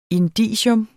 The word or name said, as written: indicium